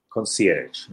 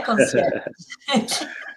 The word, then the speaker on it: concierge: And what about the last one, the French? – Concierge, no? – Yeah, very good. Beautiful touch, eh? Concierge.